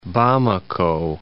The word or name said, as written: Bamako